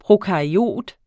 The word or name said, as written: prokaryot